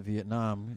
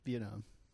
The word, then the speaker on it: Vietnam: But what's the correct pronunciation? – Vietnam. – Vietnam. – Yeah. – But the accent is on Viet.